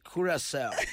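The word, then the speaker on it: Curaçao: Curacao. – Curacao. – Not Curacao more. – It actually reminded me of the Duracell batteries. Yes. – When the commentator was actually pronouncing it. It's Curacao.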